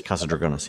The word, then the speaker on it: Casa Dragones: So the owner of Casa Dragones, am I saying that right? – Yes, Casa Dragones, yes.